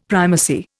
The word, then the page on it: primacy: Indian